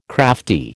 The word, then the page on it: crafty: American English